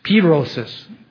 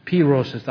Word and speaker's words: pyrosis: Porosis? Is that how you say it? Pyrosis. Pyrosis. The hard P.